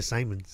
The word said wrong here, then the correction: Simone's: Did I say Simon's? Yeah. I don't know what it's pronounced. So, all right, Simone's makes sense.